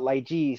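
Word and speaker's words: lai ji (荔枝): Yeah, in Chinese and Cantonese we call it lai ji, so that's how I would pronounce.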